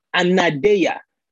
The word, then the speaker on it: anadeia: The next Greek word, or the next Greek word to learn, is anadeia. Anadeia. For those of you writing it down, I'll spell it for you. It's A-N-A-D-E-I-A. A-N-A-D-E-I-A. It's called anadeia.